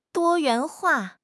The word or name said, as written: 多元化